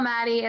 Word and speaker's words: Maty: The weirdest way to spell Maddie, M-A-T-Y.